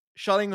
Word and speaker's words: Schelling: Schelling, by the way, I'm transferring to the English pronunciation.